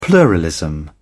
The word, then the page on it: pluralism: British English